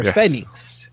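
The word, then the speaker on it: Fénix: And teaming with them, a guy who is like equally the crazy high flyer, Phoenix, or Phoenix, as it's pronounced, taking on this really cool Rudo team of Los Hell Brothers.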